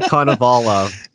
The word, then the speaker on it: Konovalov: Yeah, and I mean, that Konovalov, how did you say his name? – Ilya Konovalov.